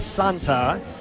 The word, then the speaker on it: Santa: It's spelled like Santa. It's pronounced Santa.